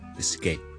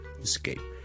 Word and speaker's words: escape: 今日有听众问萧叔叔一个英文字嘅发音，一个都几常见嘅，就系去逃走、逃离一个地方，to escape, escape, escape。好多人咧，互相嘅读成咧系 escape 嘅，呢个系 E S C A P E。咁其实 E S 咧。它是不会有个 K 音的。